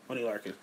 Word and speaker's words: Oney Lorcan: This is I can't pronounce this person, but O-N-E-Y L-O-R-C-A-N Oney Larkin? – Oney Larkin.